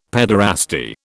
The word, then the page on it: paederasty: British English